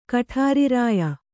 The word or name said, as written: ಕಠಾರಿರಾಯ